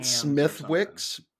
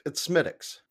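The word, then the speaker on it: Smithwick's: Some people pronounce it Smithwix, but it's Smithix.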